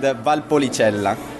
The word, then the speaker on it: Valpolicella: And Valpolicella.